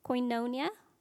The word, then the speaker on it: Koinonia: And if you remember that one term, Darwin, am I saying this correctly? Koinonia? Yes.